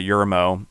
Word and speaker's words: Yermo: And based on businesses and things he helped do, he helped start Del Taco in Yerma, California, Yermo, I think it's pronounced, I can't pronounce it, in 1964, even before he created Taco Bell, I believe, because he was trying to get taco concepts.